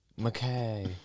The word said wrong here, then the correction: Mackey: Emma McKay. – It is, I believe, Mackie.